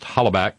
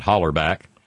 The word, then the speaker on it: hollaback: Bob pronounced hollaback correctly, as is not hollerback.